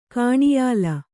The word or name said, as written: ಕಾಣಿಯಾಲ